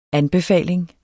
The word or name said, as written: ANBEFALINGS